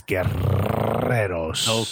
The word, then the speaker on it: Guerreros: And I'm Alexis Guerreros. – Okay. – Roll your R's. Have some respect for the 37 R's in my last name, my G.